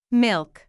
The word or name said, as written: milk